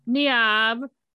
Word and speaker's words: Niamh: Her name is Niamh, which I know I'm pronouncing that kind of wrong. It's spelled N-I-A-M-H, and it's often these days spelled Neve. But it's kind of pronounced just a little different than Neve.